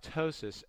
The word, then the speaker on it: ptosis: If it falls lower than that, then you may have ptosis, and that's the word, starts with a P, the P is silent.